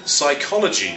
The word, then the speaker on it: psychology: Psychology, of course, has a silent P.